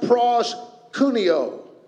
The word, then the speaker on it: proskuneo: The New Testament word for worship is pronounced proskuneo, and it means to kiss.